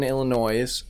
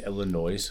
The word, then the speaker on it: Illinois: You say Illinois? – Illinois is. – Illinois. – Illinois is. – You say either way, I like Illinois. – S is silent.